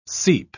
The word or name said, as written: seep